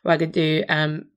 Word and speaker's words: Wagadu: And I've forgotten how to pronounce it, because Pottermore does provide a very nice pronunciation. Wagadu, there, yeah.